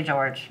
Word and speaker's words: Jorge: It's spelled the same. – Maybe it's a Brazil. – Yeah, it's Brazilian. Yeah, they still say George.